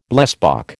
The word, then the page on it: blesbok: American English